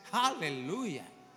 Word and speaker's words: hallelujah: En inglés es hallelujah. Es más próximo a nosotros. Nosotros no pronunciamos la H, nuestra H es muda. Por eso decimos aleluya. Pero deberíamos decir como los judíos: aleluya.